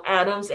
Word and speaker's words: Adams: Uh, my name is spelled A N, not with an E, uh, space Marie, M A R I E, uh, Adams, A D A M S, and sometimes it's used a hyphen but never E.